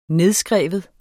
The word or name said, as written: nedskrive